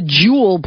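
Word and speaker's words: Joule: It's called the Juul bracelet, and it straps on the wrist and uses a patch to deliver caffeine directly through the skin.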